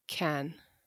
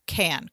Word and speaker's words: Cannes: It's Cannes. – Cannes. Great. I'm glad. I'm glad that I'm showing how cultured I am by pronouncing that correctly.